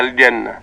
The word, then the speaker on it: الجنة: أما في آخر آية من هذه السورة المباركة، هي كلمة الجنة، لا نخطئ فيها ونقولها الجنة نعم. – وكذلك كثير من الناس ينطقون الجيم كالتي نسمعها في الشارع، وهي الجيم المصرية الجميلة، هذه في كلامنا نقبلها، يقولون الحجة والحج، هذا جميل. أما في القرآن لابد أن تكون كما تعلمناها وعلمنا نطقها شيوخنا، هكذا: من الجنة والناس. هذا آخر ما ورد في هذه الآية المباركة.